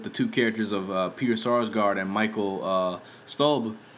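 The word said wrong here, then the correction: Stuhlbarg: How the fuck you pronounce this dude's name? Oh, Stolbark, yeah.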